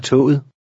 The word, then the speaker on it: toget: In number 6, the last G is silent. – Læs nummer 6. – Vi tager toget.